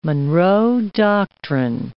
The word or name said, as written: Monroe Doctrine